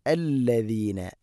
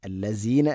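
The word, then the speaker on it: അല്ലദീന (الذين): കെമെഫെലി, കെമെസെലി അല്ല, കെമെഫെലി ലദീന, അല്ലദീന, ലസീന അല്ല, അല്ലദീന.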